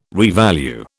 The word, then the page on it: revalue: British English